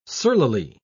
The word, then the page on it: surlily: British English